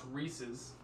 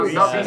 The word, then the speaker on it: Reese's: It's pronounced Reese's. – Yes. – Reese's. – Yes. Yeah, not Reese's. Thank you for the correction.